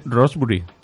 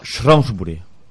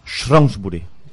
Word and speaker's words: Shrewsbury: Pero si pone Rosbury. No, no, Shrewsbury. Aquí pone Rosbury. Vamos a ver, yo he buscado cómo se pronuncia y es Shrewsbury.